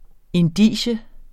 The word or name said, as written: indicium